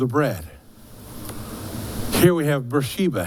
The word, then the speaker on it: Beersheba: If you want to pronounce it correctly, I do believe it's Beersheba.